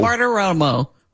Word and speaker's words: Bartiromo: Baratomomo, Romo, Baratomomo. – Bartiromo. – Bartiromo. I always have a problem with that.